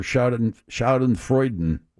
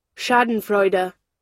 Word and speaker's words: Schadenfreude: I can do the official pronunciation right here. – Yeah, please. – Schauden. – Schadenfreude or Schadenfreuden? – We'll find out. – Full word. – Here we go. – Schadenfreude. – Freude. – Schadenfreude.